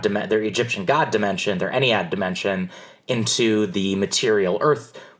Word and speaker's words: Ennead: Iliad. That's how you pronounce it? – Ennead.